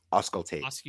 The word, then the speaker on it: auscultate: Auscultate.